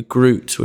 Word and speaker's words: Grut: Uh, so Yahoo Finance UK, or I'm on Twitter, Oscar W Grut, which is spelled G R U T.